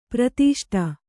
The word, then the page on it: ಪರಿತುಷ್ಟೆ: kannada